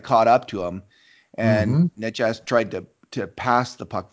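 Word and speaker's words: Nečas: Is that how you pronounce it? Netchass? – I believe so.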